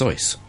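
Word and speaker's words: Seuss: I don't understand that because it should be pronounced Soyce.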